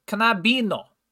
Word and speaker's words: knabino: For example, all two syllable words will have the emphasis on the first syllable, as in viral, and all three syllable words have the emphasis on the middle syllable, such as knabino.